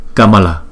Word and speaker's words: Kamala: Chữ Kamala này ba chữ A, không có dấu gạch ngang trên đầu cho nên chúng ta đọc một nhịp hết. Kamala, Kamala.